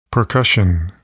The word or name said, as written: percussion